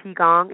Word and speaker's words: Qigong: So Qigong, it's pronounced Qigong, but it's spelled Qigong. Qigong.